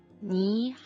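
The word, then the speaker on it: ni (你): Ni hao. – Ni hao. – In cinese, ni si pronuncia con il terzo tono e corrisponde al nostro tu, ed è quindi un'espressione informale e colloquiale.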